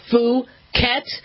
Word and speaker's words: Phuket: I know people want to make that a short U, but I have to on air say it's Phuket.